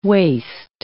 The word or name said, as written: waist